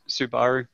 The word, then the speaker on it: Subaru: it is Subaru, and it's the right way to pronounce it.